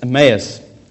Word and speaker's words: Emmaus: how do you pronounce it? Sam, you pronounced it very well. Emmaus. On the road to Emmaus.